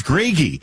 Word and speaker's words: Grage: All this time, I pronounced his last name wrong. That Dustin Grage is doing a great job, and probably half my colleagues are like, who are you talking about? It's Greggy.